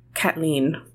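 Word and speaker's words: Caitlín: I will say in Ireland they would say Kathleen instead of Caitlin. That's how you pronounce that. The word Kate, C-A-I-T, also in Irish, means cats.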